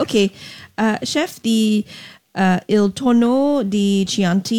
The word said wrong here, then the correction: Tonno del Chianti: uses pork rather than your traditional tuna. Where does that name come from? I hope I said it right. How is it prepared? – Tonno del Chianti.